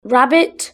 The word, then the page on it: rabbit: British English